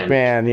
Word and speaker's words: McMahon: They had Arenado, Blackman, Desmond, Mick, I don't know how to say his name, Ryan McMahon. – McMahon, yeah.